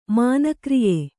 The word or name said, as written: ಮಾನಕ್ರಿಯೆ